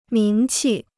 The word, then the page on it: 名气: míng qì